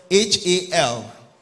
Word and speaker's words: hal: spelled H A L, and I pronounce hal, and it means whole, whole, complete, not fraction.